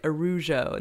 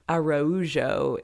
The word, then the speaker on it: Araújo: and like their last name they pronounce it like Arujo, and they make like a big deal out of that in the movie, like the pronunciation of their name, but it's actually like not, he's like, that's just not a name in Portuguese, and he's like, it's Araújo is a name,